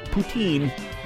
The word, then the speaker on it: poutine: It's not Putin. That is the French pronunciation. You're pronouncing it wrong in French. Call it poutine.